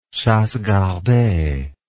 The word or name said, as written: chassegardee